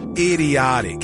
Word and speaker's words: Idioteque: I'm going to say it's pronounced idiotic, just spelled different.